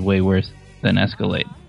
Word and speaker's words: Escalade: It's pronounced Escalade.